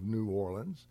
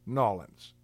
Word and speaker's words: New Orleans: Now, I have been instructed by a native of New Orleans to never pronounce it New Orleans unless it is used in that context. Otherwise, the name of the city is always pronounced Nawlins.